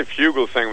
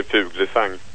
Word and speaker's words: Fuglesang: Well, I say Fuglesang when I speak Fuglesang. English. In Swedish I would say Fuglesang.